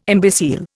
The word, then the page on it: imbecile: Indian